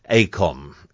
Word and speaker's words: AECOM: AECOM, if that's how you pronounce it.